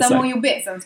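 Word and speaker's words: Samo ljubezen: Jag kan ju inte uttala det här heller. – Zamo Jubazien skulle jag säga.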